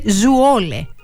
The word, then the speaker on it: Zwolle: ότι η πόλη προφέρεται Zwolle. Zwolle. Έτσι για να είμαστε και σωστοί με τις γλώσσες και την προφορά.